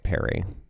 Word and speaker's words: perimeter: It's going to be like more of a short I as opposed to the long I. Sure, sure. It just has P-E-R-I. – Yep, yeah. Got it. I'm just calling it perry.